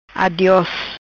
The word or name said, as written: Adios